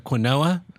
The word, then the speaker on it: quinoa: Be sure to, I'd like a side of quinoa, which is the proper way to pronounce that word.